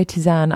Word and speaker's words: tisane: I always say Tisane. I think it's Tisane. – Is it Tisane? Yeah, because it's from so. French.